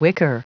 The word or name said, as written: wicker